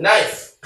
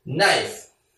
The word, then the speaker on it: knife: 여기 보시면 knife, 칼이거든요. 이 K는 발음이 안 돼요. knife.